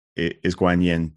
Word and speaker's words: Guanyin: As far as the pronunciation, you'll see it written with a Q or a K. You hear me pronouncing it more like a G, G-U-A-N-Y-I-N. That's the correct Mandarin Chinese pronunciation.